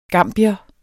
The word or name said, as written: gambier